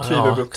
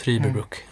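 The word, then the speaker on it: Trüberbrook: Ja, det är ett y, va? – Trüberbrück. – Ja, Trüberbrück.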